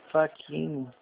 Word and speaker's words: Fachini: Faquinho. – O C é mudo, né? – Isso.